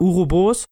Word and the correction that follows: Uroboros: okay. Uroboros, Uroboros, genau. Und die werden halt immer wieder neu geboren.